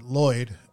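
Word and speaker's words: Lwyd: It's M-A-R-I. That's the first word. And then Lloyd, because it's Welsh, it's spelled oddly. It's L-W-Y-D.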